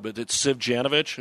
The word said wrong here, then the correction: Savanovic: Am I close? – Yeah, it's Simon Savanovic. – Savanovic. – Yep, Savanovic. So that's what happens in the off season.